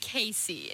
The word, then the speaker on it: Kassie: It's spelled K-A-S-S-I-E, but it's pronounced Casey.